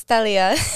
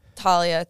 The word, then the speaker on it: Talia: So in Miami, it's Talia. – Okay. Oh, yeah, because we had a girl, we had a former guest, and it's Talia as well, but it's spelled with a T-H. So yeah, Talia, but I wasn't sure. – My, up north, Talia, Talia. Kind of just depends on how you speak.